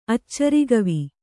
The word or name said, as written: ಅಚ್ಚರಿಗವಿ